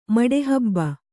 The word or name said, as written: ಮಡೆಹಬ್ಬ